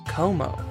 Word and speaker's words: Comeau: The last name was C-O-M-E-A-U. Como.